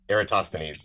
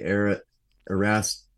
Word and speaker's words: Eratosthenes: Yeah, here's the problem with Eratosthenes, and so this is that how you say it? – Eratosthenes. – Damn it! I've been sad. All right, Eratosthenes. – Yeah, what is the one that most people say? Erastothenes. – Erastothenes. – Eratosthenes is what most people say. It's Eratosthenes.